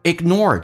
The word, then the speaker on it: ignore: ignore 这个单词记住 G 在音标是有，但是发音的时候不发，两个辅音在一起经常是用发后面一个，叫气流阻塞一下，叫 ignore，忽略你。